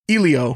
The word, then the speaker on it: Elio: Elio.